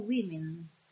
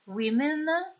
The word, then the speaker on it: women: She was a supporter of equal rights for women. Women è la pronuncia plurale della parola woman. Woman, women, woman, women. È una pronuncia che fa eccezione.